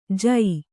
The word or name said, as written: ಜೂ